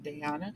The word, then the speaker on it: Dayana: Her name is, I was saying Diana. It's spelled D-A-Y-A-N-A, but Diana.